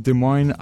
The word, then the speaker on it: Des Moines: Des Moines, Iowa. Which, wow, I think this is the first time on the show that I actually nailed the pronunciation of anything French, Des Moines.